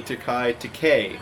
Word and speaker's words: Takei: Let's see, Takei. His name is Takei. – Takei because it rhymes with gay.